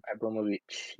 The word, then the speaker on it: Abramovich: So, Russian billionaire Roman Abramovich. Is it his name? I don't know. I know I butchered his name. – Abramovich, yep.